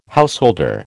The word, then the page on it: householder: American English